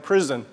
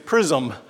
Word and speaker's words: PRISM: PRISM, okay, I'm never going to forgive Carl Templin for giving it that name because international students say it like prison. It's not prison ministry, it's PRISM.